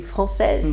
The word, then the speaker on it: Françoises: Ça s'écrit Les Folies Françoises, mais ça se dit Les Folies Françaises.